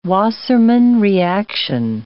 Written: Wassermann reaction

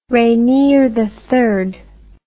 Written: rainier iii

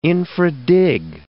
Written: infra dig

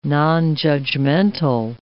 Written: nonjudgmental

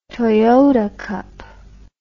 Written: toyota cup

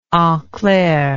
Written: enclair

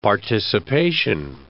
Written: participation

